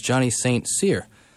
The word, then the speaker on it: Johnny St. Cyr: In other news, the correct pronunciation of the banjo player for Lily Delk's Christian song Baby O' Mine is Johnny St. Cyr.